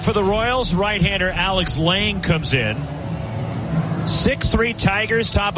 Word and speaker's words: Lange: And now it is Lang, which is spelled L-A-N-G-E.